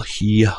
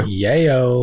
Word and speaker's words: Yeoh: Michelle Yeoh. Oh wow, I butchered that. Google save me. Thank you. – Yeoh. – Michelle Yeoh. Height.